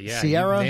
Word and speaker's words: Ciara: Is it Ciara? How is it pronounced? – Sierra, yeah.